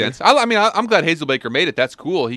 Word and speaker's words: Hazelbaker: Hazelbroker. – Hazelbaker.